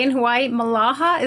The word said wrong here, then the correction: mahalo: Is that right? Did I pronounce it right? – Mahalo. – Mahala. I got it backwards.